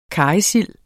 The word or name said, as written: karrysild